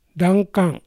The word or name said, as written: Dankan